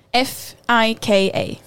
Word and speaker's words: fika: How do you pronounce that? – F I K A. – Fika.